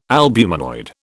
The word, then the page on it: albuminoid: British English